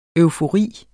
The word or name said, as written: eufori